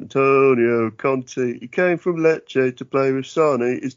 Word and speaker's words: Lecce: Where he's from, is it pronounced Lecce? – It is. Well done. – Good, because that will help with the rhyme. – What did you think it was, Lease? – I don't know, mate.